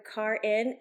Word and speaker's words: Karin: And just to let you know, guys, it is Dr. Karin. And the way she taught me how to say it was? – Like car in the garage. – Boom. – Yeah. – Never forget it from here on out. K-A-R-I-N. – Correct. Yeah. – Not Karin.